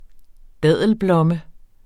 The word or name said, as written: daddelblomme